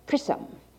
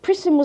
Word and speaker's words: Prism: He pronounced it Prism. "You've read Prism," said Greep, pronouncing correctly the name of an old acquaintance. "I had to. Prism was on the preparatory program, Anglo-Saxon Commercial English."